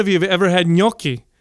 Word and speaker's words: gnocchi: Gnocchi. – Gnocchi. – So it's spelled G-N-O-C-C-H-I. Gnocchi. So that G-N makes it gnocchi. Sounds like Gucci.